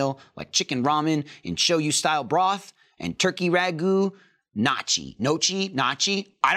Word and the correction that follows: gnocchi: Gnocchi? – Is it gnocchi? – Yes. – That's how you pronounce the squish balls? I knew I was doing it wrong. The broadcast crew is telling me it's gnocchi, so I was right that I don't know how to pronounce it, but I do know how to eat it, okay?